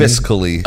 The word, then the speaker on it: fiscally: Physically? What do you mean? Like hitting women? – Fiscally. – Oh, yeah. Okay, so I always say that. Yeah, I say it wrong.